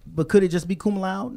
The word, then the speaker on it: laude: So just like at my son's graduation, they said cum laude. And I'm like, it's cum laude. – So there's a D, laude, yeah. Mm-hmm. Two syllables. – Yes. – Because they're E at the end.